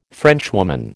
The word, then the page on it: Frenchwoman: American English